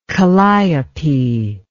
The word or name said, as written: kalliope